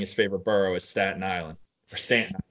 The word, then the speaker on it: Staten Island: And the Yankees just released a commercial of him where he mispronounced Staten Island as Stanton Island for 30 seconds.